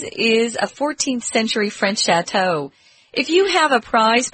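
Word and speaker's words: château: It's a French word, chateau. That's why we pronounce it kind of funny.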